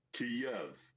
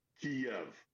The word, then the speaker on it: Kyiv: Now, the Russian pronunciation is Kyiv. The Ukrainian closest approximation that we can do as non-Slavic speakers is Kyiv. So the Y ends at the first syllable.